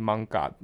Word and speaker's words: 艋舺: 用他们的发音 Manga 的意思就是独木舟的意思，或是舢板舟的意思，嗯，那简单来讲，艋舺会发迹就是跟呃跟淡水河，然后跟商业非常有关系。